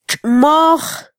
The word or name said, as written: txmoj